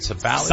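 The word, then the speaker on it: Sabally: Let's, Sabali. Am I saying it right? Sabali. – Sabali, yeah.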